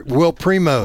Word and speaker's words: Primos: Primo? – Will Primos.